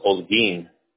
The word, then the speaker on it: Holguin: It's Holguin out here, but because it's Spanish, it's pronounced without the H. It's silent, so it would be the next letter in front of it. So it's actually pronounced Holguin.